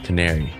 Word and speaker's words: Canary: And with me, a special guest, a returning guest, Brian Canary. Did I say that right? – Okay. You did, just like the bird.